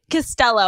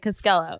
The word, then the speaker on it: Costello: I'm going to say it right. Costello. I almost said Costello.